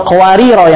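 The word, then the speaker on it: qawarira (قواريرا): Sedangkan yang sebelumnya itu cara bacanya dua ya, ada dua, dia ketika disukunkan bacanya mad, qawarira, qawarira, dibaca panjang ketika disukunkan. Kalau diwasal baru bacanya pendek.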